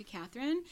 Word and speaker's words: Kathryn: Okay, so y'all can follow me on the gram, on Twitter, on LinkedIn if you want, at Bianca Catherine.